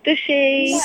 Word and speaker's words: stushy: So give us a little masterclass, because I thought the name was going to rhyme with whoosh, like stoosh, but it's not, is it? – No, stushy. – Stushy. It's kind of like squishy.